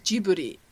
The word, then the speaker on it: Ghibli: Yeah, I think in Japanese it's pronounced Jiburi.